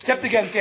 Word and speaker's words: Septuagint: The most common two words that came to us from the, is that Septuagint? – Septuagint, yes.